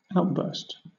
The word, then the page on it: outburst: British English